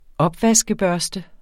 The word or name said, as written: opvaskebørste